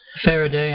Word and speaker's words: Faraday: Faraday, after the scientist, yeah, Faraday cage.